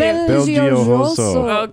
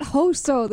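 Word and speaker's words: Belgiojoso: Belgioso. – Okay. – It's not Hoso. This is Yoso. – French. – Belgioso.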